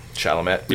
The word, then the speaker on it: Chalamet: How I don't know how to say his name. It is Chalamet, but um, Chalamet.